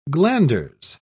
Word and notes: glanders: British English

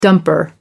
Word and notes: dumper: American English

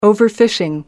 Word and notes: overfishing: American English

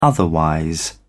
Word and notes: otherwise: British English

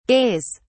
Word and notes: gays: British English

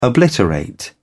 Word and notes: obliterate: British English

